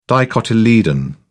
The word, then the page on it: dicotyledon: British English